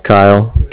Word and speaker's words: Kyle: My name's Kyle, K-Y-L-E, calling with the Bank of Cardiff.